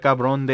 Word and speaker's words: cabrón: Quanta cabrón de San Diego.